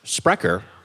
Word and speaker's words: Sprecher: Originally started as a brewery in Milwaukee in the early 1980s, Sprecher, pronounced Sprecher, did it right,